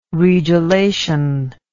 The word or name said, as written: regelation